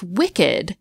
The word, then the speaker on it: wicked: So in that case, why do we still pronounce wicked with two syllables?